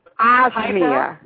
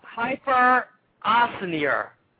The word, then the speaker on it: hyperosmia: Hyporathmia. Look that up. – Hyperosmia. – Hyper. – Like you hyper? – Okay. Yeah. Hyperosmia.